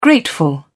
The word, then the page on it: grateful: British English